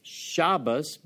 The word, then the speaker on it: Shabbos: And he says, when the Tav appears without a dagesh, it's pronounced one way. When it appears with the dagesh, the dot in the center, it's pronounced another way. And he said, so we pronounce Shabbos because of this rule.